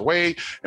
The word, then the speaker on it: Waid: Thank you for everyone that has hit me on my Facebook, Instagram, or Twitter at Real Pastor Wade, and Wade is spelled W-A-I-D.